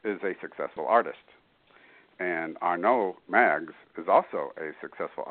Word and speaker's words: Arnaud: Uh, spring and uh, I, I, wasn't quite sure how to pronounce it, but I believe it's Spring and Arnaud. Arnaud.